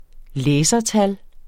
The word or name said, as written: læsertal